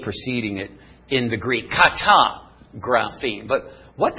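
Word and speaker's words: katagraphein: This word is pronounced kata graphene. Now, right is grapho. This word, kata graphene.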